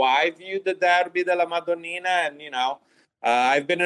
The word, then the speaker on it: Derby della Madonnina: Is there a derby you would consider to be similar to the Derby della Madonnina? – Wow, Alex, good pronunciation.